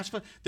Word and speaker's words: FAFSA: So, Ms. Johnson, it sounds like there's the FAFSA. Am I saying it right? – Yeah. – FAFSA?